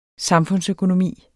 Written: samfundsøkonomi

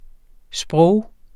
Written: sprog

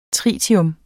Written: tritium

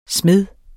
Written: smed

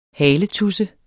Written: haletudse

